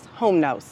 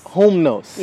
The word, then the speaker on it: iHumnos: It's iHumnos. – iHumnos. – Yeah, so think like loom, like fruit of the loom.